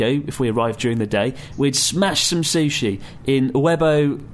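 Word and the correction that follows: Uobei: I can't pronounce that. Uebe... Why can't I pronounce that? – Uobi. – Because it's a really awkward word. – Uobi! – It's spelled U-O-B-E-I.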